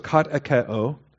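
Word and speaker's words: katecheō: The verb is katekeo, K-A-T-E-C-H-E-O. And you really want to pay attention the significance here of that eta. All right, that's the long E with the short E. It's katekeo.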